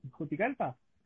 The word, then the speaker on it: Juticalpa: ¿Está, bien pronunciado, Juticalpa? – Sí, sí, sí, está bien pronunciado, sí.